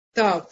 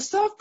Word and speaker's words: тав: То есть просто тав, э, она читается, и мы все произносим только тав сегодня. Букву сав произносят только в некоторых ашкенадских общинах. Э, вот, э, и э, мы тоже знаем, что она читается как сав.